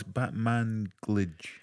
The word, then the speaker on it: Batmanglij: Lead vocalist / guitarist Ezra Koenig, multi-instrumentalist Rostam Batmanglia. – Stop. – I can't pronounce that. – Say that again. – Rostam Batmanglij. – Rostam. I'm not fussed about the surname. – Rostam. Well, it's Batmanglij.